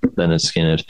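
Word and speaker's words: Lynyrd Skynyrd: It's Leonard Skinner with pronounced Leonard Skinner.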